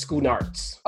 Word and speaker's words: Schoenaerts: And then it's starring Matthias, how do you say this guy's last name? Matthias Schoenaerts.